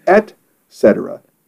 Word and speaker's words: et cetera: It is actually et cetera, E-T, cetera. This is a common mistake. It's commonly mispronounced.